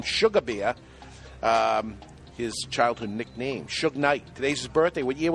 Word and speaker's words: Suge Knight: Also known as Sug Knight. – Suge Knight.